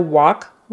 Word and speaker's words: walk: Would you like to go on a walk with me? Walk. Even though you would think it would be spelled this way, wok is a cooking utensil. Wok, wok. They're pronounced the same way, spelled differently.